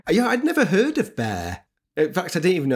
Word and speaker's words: Bere: If it's Old English, the last E is pronounced, so it's Bere.